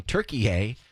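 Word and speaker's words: Türkiye: World Cup USA men playing Turkey A at 6. That's how you say it. I'm telling you. It's not just Turkey. It's Turkey A.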